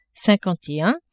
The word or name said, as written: cinquante et un